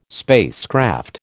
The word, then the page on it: spacecraft: American English